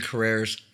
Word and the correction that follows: Carell: Probably. Steven Carrera... – Carell. – Carell, yeah, I was saying it right.